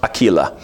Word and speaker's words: Aquila: Greet Prissa and Aquila, would have been pronounced Aquila,